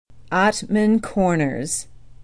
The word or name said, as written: ottman corners